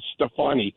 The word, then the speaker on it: Stefani: By the way, the pronunciation of the assembly member, the former supervisor in San Francisco, is Stefani, Gwen Stefani.